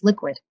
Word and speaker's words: LQWD: Today we are speaking with Sean Anstey, founder and CEO of LQWD pronounced Liquid, the first publicly traded, purpose-built Bitcoin company that's focused on solutions that power the growth of the Bitcoin Lightning Network.